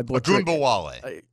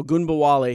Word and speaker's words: Ogunbowale: Dare, I don't know how to pronounce the last name. My Ogunbowale. – Are you sure? – Yes. – Okay. Mm-hmm. Ogunbowale, because I just call him Dare because I have butchered it many, many times.